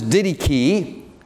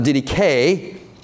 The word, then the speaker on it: didache: In the Greek, this word is didache. You can also, I found out, you can pronounce it didache. Some people call it didache, but that's not how you pronounce it. It's didache or didache.